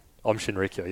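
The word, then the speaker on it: Aum Shinrikyo: Is that how you say it? – Om Shinrikyo, yeah.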